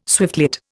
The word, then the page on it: swiftlet: Indian